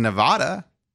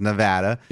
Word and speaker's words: Nevada: It's legal in Nevada, or Nevada. I'm sorry. It's pronounced Nevada.